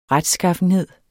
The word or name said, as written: retskaffenhed